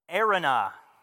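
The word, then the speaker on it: Araunah: We have, now thankfully I took the time to figure out how to pronounce this word. And it's basically Arunah.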